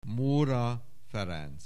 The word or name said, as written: Móra Ferenc